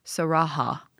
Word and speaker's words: Sarahah: spelled it's spelled like Sarah with an H at the end and then another AH. So S A R A H A H.